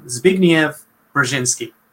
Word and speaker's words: Zbigniew Brzezinski: And I took a while for me to figure out how to pronounce his name, because if you see it written down, it's a Scrabble mess. But I assume it's Polish. It's S-B-I-G-N-I-E-W and then Brzezinski. So Zbigniew Brzezinski.